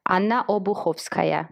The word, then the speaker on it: Anna Obuhovskaya: Anna Obuhovskaya yeah, well, it's a little bit different.